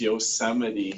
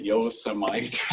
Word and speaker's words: Yosemite: Trump couldn't pronounce Yosemite. He called it Yosemite.